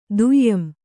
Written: ದುಯಂ

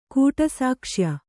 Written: ಕೂಟಸಾಕ್ಷ್ಯ

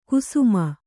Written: ಕುಸುಮ್ಮು

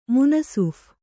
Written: ಮುನಸೀಫ

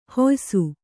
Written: ಹುಯ್ಸು

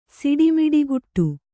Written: ಸಿಡಿಮಿಡಿಗುಟ್ಟು

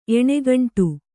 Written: ಎಣಗಂಟು

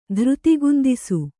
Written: ಧೃತಿಗುಂದಿಸು

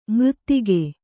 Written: ಮರೆದೆಗೆ

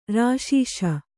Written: ರಾಶೀಶ

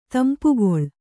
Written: ತಂಪುಗೊಳ್

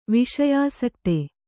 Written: ವಿಷಯಾಸಕ್ತೆ

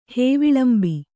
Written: ಹೇವಿಲಂಬಿ

